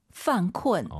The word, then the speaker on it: 犯困: 犯困, 犯困, 이 발음을 잘하셔야 됩니다. 쿤쿤 하지 마시고 UN 사이에 으어 들어가서 다시 한번 犯困.